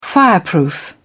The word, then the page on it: fireproof: British English